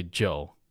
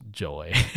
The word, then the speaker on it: Joe: So it is actually Joe. It's not pronounced Joy.